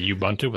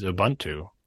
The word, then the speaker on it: Ubuntu: And the one I usually hear is Ubuntu without a long U. But I'm sure people say it with a long U as well.